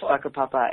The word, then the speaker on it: Whakapapa: Yes, ladies and gentlemen, it's called Whakapapa. It's spelled W-H-A-K-K-A-P-P-A, and it's a beautiful, beautiful place.